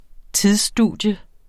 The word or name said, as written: tidsstudium